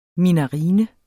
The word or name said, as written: minarine